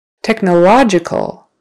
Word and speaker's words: technological: Technological. So now it's on the third syllable.